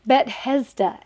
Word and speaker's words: Bethesda: Why did you just say it like that? Bethesda. And he's like, oh, just the same as like Bethlehem, which we know Bethlehem.